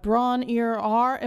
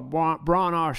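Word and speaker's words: Bron-Yr-Aur: Brawn Ear R. Am I saying that right? – I always just call it Brawn R. Stomp.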